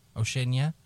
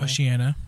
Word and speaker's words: Oceania: Oceania? I believe it's pronounced that way. – Oceania. – That IA always fucks me up.